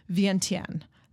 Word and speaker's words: Vientiane: which is now called Vientiane. That I looked up, so I know I'm pronouncing it right.